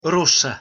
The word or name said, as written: rossa